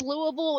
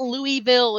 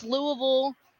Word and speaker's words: Louisville: We were watching MasterChef last night, and Joe Bastianich pronounced Louisville as Louisville, and I were like, "Oh, anybody from Kentucky knows it's Louisville. It's not Louisville. It's Louisville. – Louisville. – Louisville. It's Louisville. Spelled it's spelled Louisville, but it's pronounced Louisville.